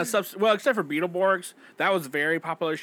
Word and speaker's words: Beetleborgs: You said that right? Yeah, Beetleborgs.